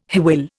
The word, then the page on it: hwyl: Indian